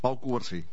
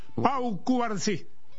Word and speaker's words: Pau Cubarsí: Habla Pau Cubarsí. ¿Cómo es que se pronuncia? – Pau Cubarsí. – Pau Cubarsí.